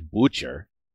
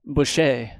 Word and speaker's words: Boucher: Boucher. Some people will say Butcher, but now I'm just, you know, this is your new thing. – Here. – Like, I like that you're getting playful with the pronunciation. – This is a heat check. B-O-U-C-H-E-R. – Boucher. – Let's say Boucher.